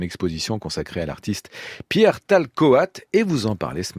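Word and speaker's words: Tal-Coat: Talcoat, je, présente, je prononce bien Parfaitement.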